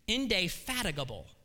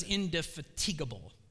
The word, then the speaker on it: indefatigable: Here's how it is. I bet you're going to be surprised. It's indefatigable. I always thought it was indefatigable.